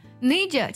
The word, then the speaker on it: need you: Need you 其实就是 need you Need you 它会把 you 呢在口语当中呢弱读成 ya 的音